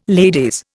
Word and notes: ladies: Indian

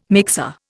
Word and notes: mixer: Indian

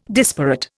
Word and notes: disparate: Indian

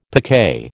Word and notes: picquet: American English

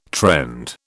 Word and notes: trend: British English

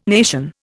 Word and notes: nation: Indian

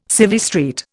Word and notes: Civvy Street: Indian

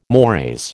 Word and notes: mores: American English

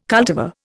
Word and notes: cultivar: Indian

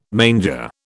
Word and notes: manger: British English

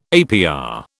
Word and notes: APR: British English

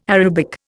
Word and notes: Arabic: Indian

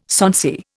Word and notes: sonsy: Indian

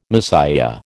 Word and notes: Messiah: American English

